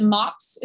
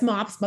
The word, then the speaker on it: MOPS: Is it MOPS? Is that what you all say? Or is it MOP? – No, it's MOPS, Mothers of Preschoolers International.